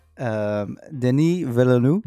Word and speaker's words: Denis Villeneuve: My number one is Denis Villeneuve.